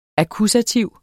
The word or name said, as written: akkusativ